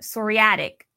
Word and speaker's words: psoriatic: You have psoriatic arthritis. Am I saying that right? Which is a different type. I'm glad you put that up here. All right. Now let's go on to talk about psoriatic. That's how you say it.